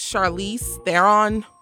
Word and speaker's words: Charlize Theron: However, I think it's Charlize Theron. That's how you pronounce her name.